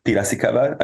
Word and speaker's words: Piracicaba: We can move on to Piracicaba, I think we'll go with that. – Yeah, I checked the pronunciation actually, and it's exactly that, yeah.